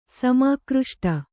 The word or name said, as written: ಸಮಾಕೃಷ್ಟ